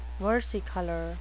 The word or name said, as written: versicolor